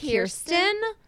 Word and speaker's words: Kirsten: I am so upset that I have been pronouncing it wrong because I was definitely that child who, like, somebody was like, I have Kristen. I was like, it's Kirsten.